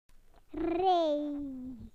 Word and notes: line up: British English

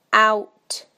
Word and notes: out: British English

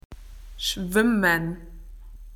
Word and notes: swimming: Australian English

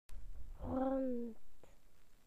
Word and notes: round: British English

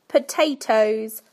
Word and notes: potatoes: British English

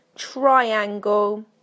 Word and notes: triangle: British English

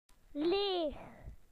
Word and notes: empty: British English

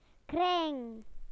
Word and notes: circle: British English

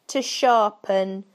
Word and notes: to sharpen: British English

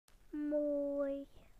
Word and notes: beautiful: British English

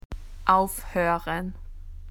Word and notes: to stop: Australian English

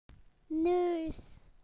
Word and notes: nose: British English

